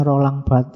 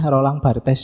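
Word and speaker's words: Barthes: Jadi dari Roland Bat, ini bacanya Roland Bat ya. Yo, kalau susah Rolang Bartes juga ndak apa-apa nama aja kok.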